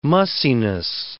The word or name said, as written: mussiness